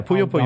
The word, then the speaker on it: Puyo Puyo: Puyo Puyo, am I saying that right? – Yeah, Puyo Puyo.